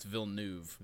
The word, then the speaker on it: Villeneuve: But David Villanuevo has said interviews. – I googled it and had them pronounce it for me. It's Villeneuve.